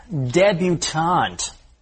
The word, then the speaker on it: debutante: However, there is a related word that is pronounced with the T sound, debutante. – That's right. A young woman who is making a formal debut into society is a debutante, not debutante.